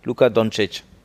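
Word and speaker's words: Luka Dončić: Luka Doncic. Ah, okay. Richtig ausgesprochen, ne? Ja. Luka Doncic.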